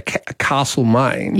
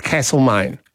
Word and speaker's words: Castlemaine: Well, and for example, if you live in the lovely Victorian hamlet of well, as Melburnians sometimes say, Castlemaine, yes. – Locals look at you and go, it's Castlemaine. – And that would be the original pronunciation of castle.